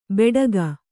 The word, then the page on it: ಬೆಡಗ: beḍaga